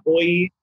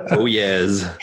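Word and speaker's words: Oyez: It's not Oye or Oh yes.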